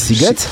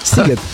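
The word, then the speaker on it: Sziget: wierzysz, że pisze się Shiget, a wymawia się Siget? Siget.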